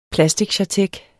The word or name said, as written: plasticchartek